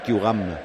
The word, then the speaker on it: Thuram: Thuram, así le pronunciaban en Francia, que sí, Thuram.